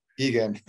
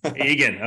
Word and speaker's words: Egan: Egan. – Egan,